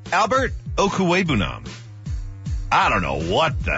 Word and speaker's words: Okwuegbunam: Yeah, it's pronounced Okwuegbunam. It's all together Okwuegbunam.